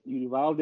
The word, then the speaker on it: Uvalde: Uh, you, I hope I'm saying this correctly. Uvalde, Texas. I'm, i think that's how it's pronounced. Nailed yeah. it. Uvalde.